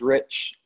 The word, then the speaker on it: Rich: So my Instagram is just Tyler, last name is Rich, spelled like Rich.